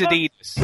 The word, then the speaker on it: Adidas: No, it is Adidas. Right, okay. The reason I know it's Adidas, because that's how it was pronounced in 1979.